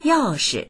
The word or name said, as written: yao4shi